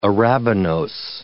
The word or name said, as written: arabinose